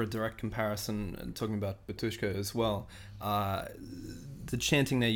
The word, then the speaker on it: Batushka: So exactly, because like the Batushka album that we were referring to earlier, and that is the correct pronunciation, no matter what anyone says,